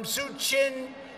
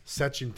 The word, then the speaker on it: Sachin: This is the country where your people cheer on some of the world's greatest cricket players, from Suchin Tendulkar to Virat Kohli. – Donald Trump pronounced Sachin Tendulkar Suchin, which was quite funny and went viral for all the wrong reasons, did old Donald Duck.